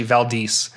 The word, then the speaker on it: Valdez: I kept calling him Vladis in the messages when we were talking. And he finally was like, it's actually Valdez.